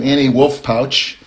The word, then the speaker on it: Annie Wolff Patsch: So I have the good fortune to speak to Annie Wolff Patsch today.